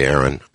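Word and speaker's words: Airyn: Now, it's spelled A-I-R-Y-N. I think I've explained this previously. I refuse to say Irene. I say Aaron. I kind of like Aaron. She kind of likes Aaron, as a matter of fact. And even a lot of Filipinos who pronounce her name pronounce it as Aaron.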